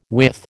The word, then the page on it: with: American English